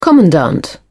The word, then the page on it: commandant: British English